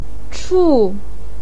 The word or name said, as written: chu4